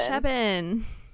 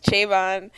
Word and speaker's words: Cheban: Apparently, it is pronounced Chebin. – Chebin. – That's gross. I thought it was Chabon.